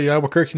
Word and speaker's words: Albuquerque: Is his last name Albuquerque? – How do you spell it? How's it spelled? – A-L-B-U-Q-U-E... R-Q-U-E. – Pretty damn close to what Albuquerque, New Mexico would be.